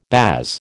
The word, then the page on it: baas: American English